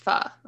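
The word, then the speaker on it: phở: It's pronounced pho, right? Mm-hmm. I always want to say pho.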